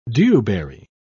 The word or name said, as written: dewberry